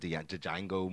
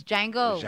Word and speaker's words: Django: I think Leonardo DiCaprio was a famous one because of that Django movie. – Why are you pronouncing that shit like that? It's Django.